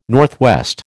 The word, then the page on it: NW: American English